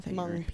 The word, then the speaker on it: Hmong: Oh, H-M-O-N-G. – Okay. – Hmong people. The H is silent.